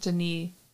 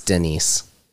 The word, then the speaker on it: Denis: I think it's pronounced Denis, because he's French. – I don't care. It's D-E-N-N-I-S.